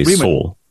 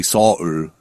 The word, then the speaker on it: Seoul: But we also mispronounce it. We say Seoul. – We say Seoul, but it's really Seoul. Seoul. – It's Seoul. It's two. Seoul. It's two. It's two syllables.